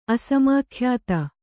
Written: ಅಸಮಾಖ್ಯಾತ

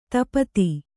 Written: ಧೂಪಿತ

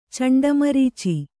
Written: ಚಂಡಮರೀಚಿ

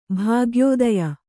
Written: ಭಾಗ್ಯೋದಯ

